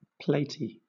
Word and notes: platy: British English

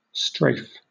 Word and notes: strafe: British English

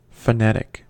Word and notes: phonetic: American English

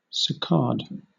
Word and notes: saccade: British English

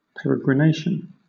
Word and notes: peregrination: British English

